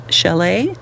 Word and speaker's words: Calais: Although the Nazis expected the Allies to land at Challis, oh, I'm not saying that correctly, spelled C-A-L-A-I-S, Chalet, a spot closer to the English coast, military commanders decided on Normandy, instead in the hopes of taking the enemy by surprise.